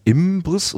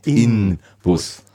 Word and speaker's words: Inbus: Häufig wird der Name durch Assimilation entgegen der markenrechtlichen korrekten Schreibung Inbus ausgesprochen. Also mit M spricht man es häufig. Der Name leitet sich von. Also, äh, genau, ich, kenne, ich habe ihn kennengelernt als Imbus und dann habe ich gelernt, es heißt eigentlich Inbus.